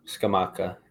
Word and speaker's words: Scamacca: Gianluca Scamacca or Scamacca? – Scamacca, yeah. – Scamacca.